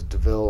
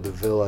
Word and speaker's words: de Vil: D-E-V-I-L. – Yeah, it's actually just Devil, but yeah. They pronounce it Deville. I thought it was Deville, like the Coupe Deville.